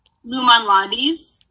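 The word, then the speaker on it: Lou Malnati's: It's L O U and then space M A L N A T I S. – How do you say it? – Lumen Lotties. – Lumen Lotties.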